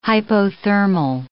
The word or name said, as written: hypothermal